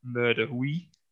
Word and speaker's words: Mur de Huy: Um, but the next question is uh, Flèche Wallonne finishes up the fearsome uh, Mur de Huy. Uh, obviously butchered the pronunciation there as well, but it's a that was fabulous. I think that was really good.